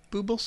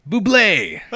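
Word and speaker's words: Bublé: It's also a Michael Buble song? – Buble! – Oh, Michael Buble. Okay. Buble? Almost got it right. – I'm so excited I got to correct you on the pronunciation of something.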